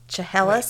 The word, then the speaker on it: Chehalis: Chillalis, Washington. – Cholula? Yeah. How do you spell it? – C-H-E-H-A-L-I-S. Chehalis? – C-H-E-H-A-L-I-S.